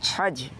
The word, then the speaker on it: हज: जी हज इसकी सही प्रोनाउंसिएशन है, हज नहीं है बल्कि क्या है? – हज।